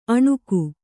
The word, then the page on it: ಅಣುಗು: Kannada